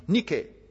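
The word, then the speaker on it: Nike (νίκη): You know Nike? Nike. That's how they pronounce it in Greek.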